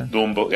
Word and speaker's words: Dumbo: Dumbo.